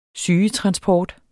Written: sygetransport

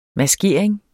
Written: maskeringer

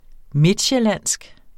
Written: midtsjællandsk

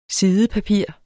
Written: sidepapir

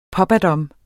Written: pappadam

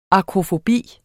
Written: akrofobi

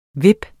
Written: VIP

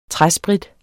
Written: træsprit

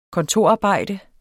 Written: kontorarbejde

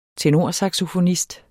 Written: tenorsaxofonist

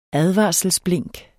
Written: advarselsblink

